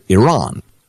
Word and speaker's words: Iran: So the entire world, including Iran, pronounces it Iran. Americans in the 1970s got in the habit of pronouncing it Iran, in the same way that at first we said Vietnam and that sort of thing.